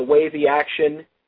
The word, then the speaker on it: Wavixtion: I want to say it's the Wavy Action, which I think is how they want it pronounced, W-A-V-I-X-T-I-O-N.